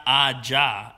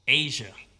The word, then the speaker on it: Aja: It's not Aja. It's like Asia, but it's spelled AJA. – Who? – Asia.